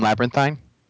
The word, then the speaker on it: labyrinthine: You mean labyrinthine? – No, labyrinthine is the correct pronunciation, just so you know.